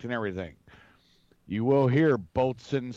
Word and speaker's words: boatswain: But the proper pronunciation is boatswain mate.